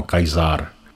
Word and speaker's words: Caesar: Snad nejznámější říman Gaius Julius Caesar, správně vyslovováno Kajzár, má údajně svůj původ v latinském slovu kedere, což znamená krájet nebo řezat.